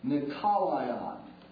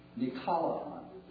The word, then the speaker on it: Nikolaon: Philip, who later on becomes a missionary, and Prochorin, and Nicanor, and Timona, and Parmenon, and listen carefully to this word, listen carefully, Nicolaion. You would spell it N, if it were transliterated into your English, N-I-K-O, or C-H-O, C-H would be better, N-I-C-H-O, no, N-I-K, where am I? Okay. N-I-K-O-L-A-O-N. Nicolaion.